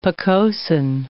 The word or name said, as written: pocosin